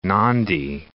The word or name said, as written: Nadi